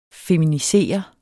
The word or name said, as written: feminisere